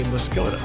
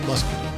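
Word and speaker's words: Muscoda: Some people will say Muscogee, which is incorrect. It's spelled like that. – It is not correct. It is Muscogee.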